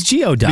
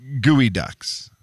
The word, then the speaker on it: Geoducks: Why did I think it was Geoducks? – Because it's spelled Geoducks. But they're Gooey Ducks.